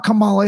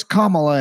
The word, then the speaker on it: Kamala: You know, he said, it's not Kamala, it's Kamala, and you should be calling her Madam, you know, Vice President.